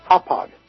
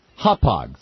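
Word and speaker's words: Hauppauge: How do you pronounce that? – Hopog. – Hopog. Thank you very much.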